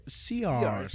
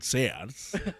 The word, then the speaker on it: Sears: Que no se dice Sears, se dice Sears. Y luego pasaron al comercial. Es que en Sears...